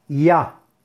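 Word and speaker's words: Ya (יה): Las letras o palabras son la letra Yud y la letra Hey, que se pronuncia Ya y que es la primera parte del nombre de Hashem.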